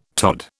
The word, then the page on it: tod: British English